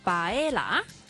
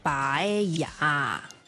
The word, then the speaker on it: paella: 但是在西班牙文呢我们读 Yama，例如呢非常之出名的西班牙炖饭啦，你会听到有些人呢可能读 Baella，但是原来正确的读音呢是读 Baella。